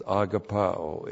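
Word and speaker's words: agapaō: And then not only is gnosko one of John's favorite, but you know this, agapao is. A-G-A-P-A long O. Agapao.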